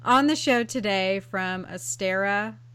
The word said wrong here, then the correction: Ostara: Am I pronouncing that correctly? Astera? – I say Ostara.